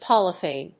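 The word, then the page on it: Polythene: pol・y・thene; pάliθìːn (en-us); pˈɔliθìːn (uk)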